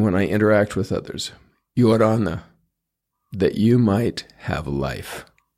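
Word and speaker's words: Ia ora na: Some people say it's easy to remember how to pronounce it when you look at a judge in the court and you say, Your Honor. That's a little bit like it. Yorana. Yorana. With the Spanish R.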